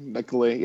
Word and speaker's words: Nicolet: In my defense, I lived in Minneapolis, and it's pronounced Nicolet there. So, well, it has two T's there.